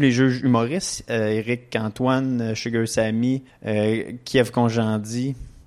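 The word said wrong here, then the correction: Kyan Khojandi: je le prononce bien son nom? Kian. Kian Conjandi.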